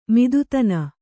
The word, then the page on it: ಮತ್ತತನ: mattatana